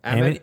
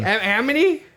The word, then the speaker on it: Amity: Amity. How do you say it? – Amity. It's Amity. – Phonetically how it's... – Are you sure it's Amity? – Yeah.